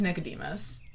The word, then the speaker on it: Nicodemus: It's Nicodemus.